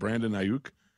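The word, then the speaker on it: Brandon Aiyuk: I was really surprised, especially like Brandon Ayuk.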